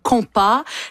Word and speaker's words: compa: Oui, ça s'écrit de plein de différentes façons, mais on dit compa.